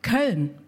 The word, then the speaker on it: Köln: Köln? Yeah. Köln.